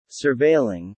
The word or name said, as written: surveilling